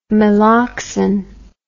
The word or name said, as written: meloxine